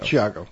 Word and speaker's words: Thiago: is it Tiago or Chiago? Chiago. Chiago, Luiz Ticchetti, uh, to talk about the seldom covered subject of UFOs in South America. It's spelled Tiago, but it's pronounced Chiago.